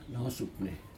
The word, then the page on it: nohos upene: Aneityum